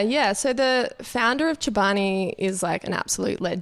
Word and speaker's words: Chobani: So Chobani, is that how you say it? Chobani? – Chobani. – Chobani. – Yeah.